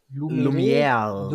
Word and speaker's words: Lumière: Ähm, wie spricht man es richtig aus? Ich habe Französisch gehabt in der Schule, aber äh, mein Französisch war nicht das Beste. Die Brüder Lumiere. – Lumière. Lumière.